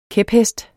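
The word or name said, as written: KÆPHESTEN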